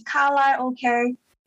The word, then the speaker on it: karaoke: Yeah, Cantonese is kalai okay.